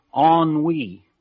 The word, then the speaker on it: ennui: Well, along the same line, there's a word I come across a lot, and until I looked it up, I called it N-U-I, because that's where it's spelled, E-N-N-U-I, N-U-I. But no, it's pronounced ennui. I found that out, ennui.